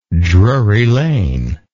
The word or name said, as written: Drury Lane